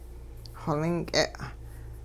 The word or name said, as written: xolink'e